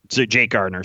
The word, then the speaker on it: Gardiner: Oh, Jake Gardiner. I forgot about him. He's got a $4 million cap hit. – Jake Gardiner? – Jake Gardiner, sorry.